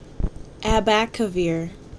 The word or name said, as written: Abacavir